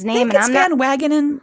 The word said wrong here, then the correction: Van Wagenen: Wagenen. That sounds. I'll, I'll go with that. Van Wagenen,